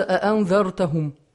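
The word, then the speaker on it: أَأَنذَرْتَهُمْ: Как подобно тому, как читается в нашем Коране, то есть в ривайте от Хафса, ривайте Хафса от имама Асима, читается как «а-а», две хамзы оставляются, «а-ан-дар-тахум».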